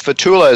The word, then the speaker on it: Fethullah: Anyway, it's Fethullah, is spelled Fetullah. Fethullah.